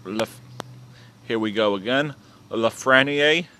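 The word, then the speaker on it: Lafrenière: I think I said it right, Lafreniere. – French. Yeah, Lafreniere. Yeah, something like that and then Quinton Byfield, Alexander Holtz, Lucas Raymond, and Jamie Drysdale.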